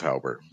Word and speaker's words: Hauber: And now I pronounced your name probably a little bit German. It's probably more Hauber, isn't it? – Well, it is German, so it is Hauber. So you did it.